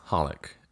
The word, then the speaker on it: Holic: It's actually pronounced as Holic, and that's how I'm going to refer to it throughout the podcast.